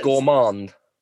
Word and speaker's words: Gourmand: and they've got one called a Bib Gourmand. I hope I've said that right. My French is absolutely terrible. – It's gourmand. – Gourmand, do you think?